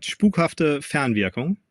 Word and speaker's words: spukhafte Fernwirkung: It's called spukhafte Fernwirkung.